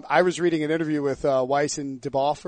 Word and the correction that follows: Benioff: Benioff? – Yeah, Benioff, whatever.